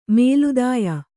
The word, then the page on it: ಮೇಲ್ದಾಯ: Kannada